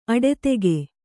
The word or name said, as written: ಅಡದಿಗೆ